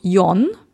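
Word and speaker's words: Yon (よん): Vielleicht kennst du Yon, das ist die japanische Aussprache, und die an das Chinesische angelehnte Aussprache ist Xi. Und Xi ist die gleiche Aussprache wie Tod.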